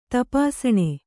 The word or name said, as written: ತಪಾಸಣಿ